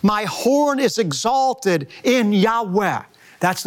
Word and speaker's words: Yahweh: covenant name that he revealed to Moses most scholars believe it's pronounced a reverent breathy Yahweh is translated in our English Bible simply as Lord, capital L, capital O, capital R, capital D.